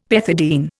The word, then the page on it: pethidine: Indian